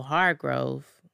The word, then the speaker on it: Hargrove: Annette Cosgrove, or is it Cosgrove? Hold up, because I think I'm saying it wrong. Yeah, no, Hargrove. I said Cosgrove.